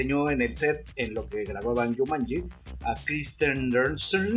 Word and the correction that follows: Kirsten Dunst: Corrígeme por favor con la pronunciación, Dashnak. – a Kirsten Dunst.